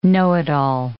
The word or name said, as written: know-it-all